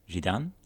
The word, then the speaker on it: Zidane: And in the Japanese spelling of the name, it's pronounced with a French... Zidane? – Okay. – Is how the French would say the name.